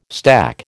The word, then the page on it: stack: American English